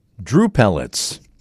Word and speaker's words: drupelets: Drupelets. There, you got to say the extra syllable there.